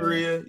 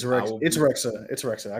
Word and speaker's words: Rexha: Hey, shout out to BB Rhea. You feel me? But it's Rexha. I got you. – I thought it was Rhea. I thought the X. – It's Rexha. Albanians, they pronounce their X's.